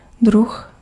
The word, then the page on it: druh: [drʊx]